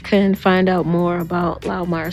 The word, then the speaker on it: Laumeier: No, it is Laumeier. It's like I said, is the name of the people who left the house in poverty, and they were German. It's a German name.